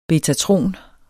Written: betatron

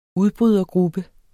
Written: udbrydergruppe

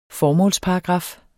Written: formålsparagraf

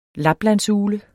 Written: laplandsugle

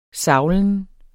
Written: savlen

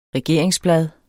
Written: regeringsblad

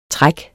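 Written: træk

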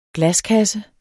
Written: glaskasse

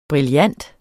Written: brillant

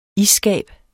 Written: isskab

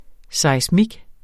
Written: seismik

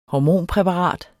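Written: Hormonpræparat

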